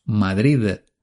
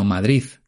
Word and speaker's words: Madrid: Eh, Juan Gómez Jurado nació en Madrid y los madrileños no decimos Madrid terminado en D, sino Madrid terminado en Z.